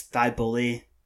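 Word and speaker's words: Thybulle: The one that annoyed me was the guy saying, no, it's Thybul. And it's just, like, the way Thybul is spelled, but you say the E. – Yeah. – And I was like, dude, the word bull, like, B-U-L-L-B-O-U-L, has been a slang word in Philly for, like, 15 years.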